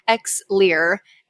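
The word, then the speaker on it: Xlear: So it's often people pronounce it Exlear, but there actually is no C. I don't know why, they should have just thrown a C in there, but either way, Exlear, L-E-A-R.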